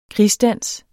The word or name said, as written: krigsdans